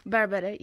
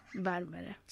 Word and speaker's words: Barbara: Barbara, yes. – Barbara? – Barbara. – So you cut the second half a little short.